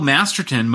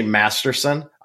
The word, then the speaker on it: Masterton: I stayed up all night trying to pronounce it because every time I've read that name for an award, I say Masterson. I don't know why. It's just how I am. I know it's Masterton, but I have been corrected many a time on that.